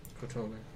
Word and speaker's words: Kotone: He is part of the Dragon Mirumoto clan, so it's Mirumoro, M-I-R-U-M-O-R-O, Kotone, K-O-T-O-N-E, and he's a Bushi. – What was the name again? – M-I-R-U-M-O-R-O and then Kotone or Kotone. I took Spanish. K-O-T-O-N-E for the first name. – Kotone.